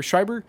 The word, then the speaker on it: Schreiber: I'm saying his name wrong. yeah. Pablo Schreiber, Schreiber, um,